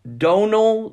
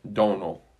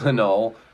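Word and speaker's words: Domhnall: Donal Gleason. He said, basically, the M is only there to confuse Americans. – Donal. – Oh. That's how he pronounced it. – Dominal is how I always said it.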